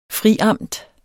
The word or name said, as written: friamt